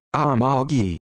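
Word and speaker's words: ama-gi: and is pronounced Amalgi.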